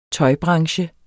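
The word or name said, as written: tøjbranche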